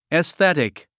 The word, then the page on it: aesthetic: esθétik; エスティティック